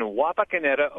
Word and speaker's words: Wapakoneta: I'm concerned, that I've mispronounced the name of your hometown. – Hi. – Where are you? Where are you located? – I'm in Wapakoneta, Ohio. – Wapakoneta, okay.